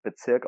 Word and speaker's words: BZRK: Äh, über die spreche ich jetzt aber nicht, sondern über BZRK oder auch einfach Bezirk ausgesprochen.